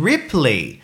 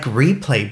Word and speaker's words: Ripley: I said, oh, you mean Ripley? And he said, oh, is that how you say it? Ripley, because in French it's Replay.